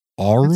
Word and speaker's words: aurum: A-U-R-U-M? Aurum? Aurum? – That sounds all right.